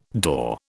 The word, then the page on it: door: British English